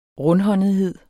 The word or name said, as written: rundhåndethed